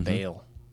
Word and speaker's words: Baal: Baal. Like bale of hay.